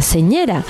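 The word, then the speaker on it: senyera: como por ejemplo los de la señera, la bandera catalana. ¿Lo he dicho bien, Marquitos? – Muy bien, la verdad que sí.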